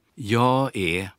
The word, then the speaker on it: jag är: Добавим, что шведы часто не произносят, проглатывают последнюю согласную, и вместо «jag är» слышно «я е».